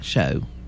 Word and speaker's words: shews: S-H-E-W-S is an old archaic spelling for show. It's pronounced like show.